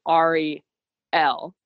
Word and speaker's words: Ariel: I think this is the only time we hear about Princess Ariel, whose name in the audiobook is pronounced literally Ari L.